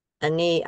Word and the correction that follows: Ina: Aini, I can never pronounce her name. – Ina. – Ania, see? – Ina. – Ina. – Yeah, three letters. Ina.